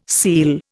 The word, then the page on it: seel: Indian